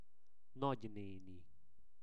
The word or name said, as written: nagyneni